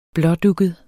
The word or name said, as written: blådugget